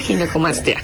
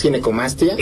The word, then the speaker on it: ginecomastia: palabra, le decía, ¿cómo se pronuncia? ¿Ginecomastia? Ginecomastia. – Sí, exacto. ¿Es ginecomastia? – Ginecomastia, ok.